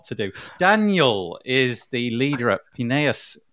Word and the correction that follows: Pinellas: Have I pronounced that right? Is it Pineus? – Pinellas or Pinellas.